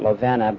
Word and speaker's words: Lovinia: And then following me is my sister, Lovina, spelled L-O-V-I-N-I-A. We always pronounce it Lovina, but I guess it should be Lovinia.